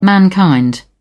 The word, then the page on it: mankind: British English